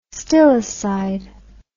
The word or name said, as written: stillicide